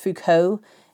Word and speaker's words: Foucault: And then he influenced a whole generation of people who were becoming skeptical, including Foucault, a French philosopher in the 1940s who had a huge impact on thinking in the 20th century. It's pronounced Foucault.